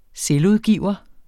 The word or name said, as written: selvudgiver